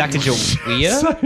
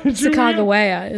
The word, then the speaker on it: Sacagawea: Sacagawea? – Sacagawea is actually... – Excuse me? – The proper pronunciation.